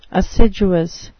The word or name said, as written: assiduous